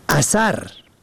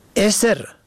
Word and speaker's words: اثر: اثر، الف سه ره به فتح اول و دوم. نشانه، اثر، الف سه ری با کسر اول و سکون دوم.